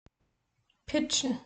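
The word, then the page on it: pitschen: Bavarian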